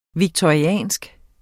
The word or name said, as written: victoriansk